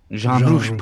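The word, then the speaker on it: Jean Rouch: Daha sonra 1961'de etnolog ve yönetmen Jean Roche mu, nasıl okunuyor? – Jean Roche.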